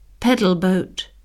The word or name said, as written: pedal boat